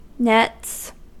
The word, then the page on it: nets: American English